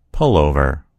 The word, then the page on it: pullover: American English